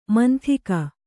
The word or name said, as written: ಮಂಥಿಕ